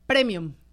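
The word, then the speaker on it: prémium: Luego, otra que me gusta, premium. Premium ya como una palabra en español, por lo tanto, tiene que llevar acento en la E.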